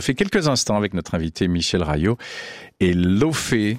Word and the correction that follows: Laufey: Leivey. – Comment ? – Elle est islandaise, Leivey. – Ça se dit comme ça ? – Ça se dit Leivey. Ça s'écrit L-A-U-F-E-Y, Leivey. – Ça fait des mois que je dis faux.